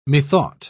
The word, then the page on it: methought: British English